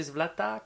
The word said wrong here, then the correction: Slava: comment on dit ça la, fête du Saint-Patron. Euh, euh, Slava. Slava.